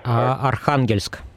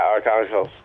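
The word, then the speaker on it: Arkhangelsk: He's more of a history buff than I am, but he told me of this situation in Archangel where, well, I guess I'm not saying it correctly. It's, how would you say it? – Archangelsk. – Yeah, Archangelsk. Anyway, I'll just keep calling it Archangel because my accent's terrible.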